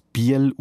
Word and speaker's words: Bühl: Im Wallis und im Kanton Obwalden spricht man das Wort Bühl übrigens als Biel aus, so wie man dort auch Friecher statt Früecher sagt oder Mies statt Müs.